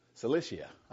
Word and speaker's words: Cilicia: Then I went into the regions of Syria and Sicilia. How do you say that? I get things in my mind, then I can't say it. Say it again. Cilicia. I knew that.